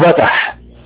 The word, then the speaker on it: فتح: الفتح القصير اسمه بتح، بتح بتح بتح. يعني ايه بتح؟ يعني فتح واضح من الكلمة، مكونة من ثلاث حروف فاء وتاء وحاء، لكن اول حرف اللي هو فاء ينطق باء في هذه الكلمة.